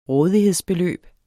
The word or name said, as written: rådighedsbeløb